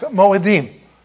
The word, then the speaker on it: Moedim: Well, the Hebrew here, don't worry about this, but you would pronounce this Moedim.